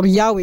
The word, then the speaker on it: Yahweh: Ito ay, ang original niya ay the proper name or the very name, personal name of God, na Yod-Heh-Vav-Heh or Yahweh sa mga, sa pronunciation, pag binabanggit natin.